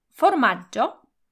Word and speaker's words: formaggio: W słowie formaggio pomiędzy literkami G i I nie ma literki H. Dlatego w tym przypadku G wymawiamy miękko.